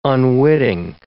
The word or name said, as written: unwitting